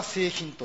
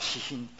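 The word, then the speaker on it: 清貧: 従順、私品というのは、これは普通は聖品というふうに書きます、読みますよね。清く貧しいと書いて、普通は聖品と読むのでしょうけれども、教会では私品と言います。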